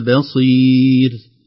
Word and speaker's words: بصير: المحذور الأول الباء مرققة لا تتأثر بالصاد، لا نقول بصير ب ب بما تعملون بصير.